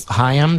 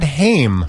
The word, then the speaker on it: Haim: I always said Haim. – Yeah. – Because it's spelled like Corey Haim.